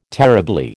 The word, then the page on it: terribly: American English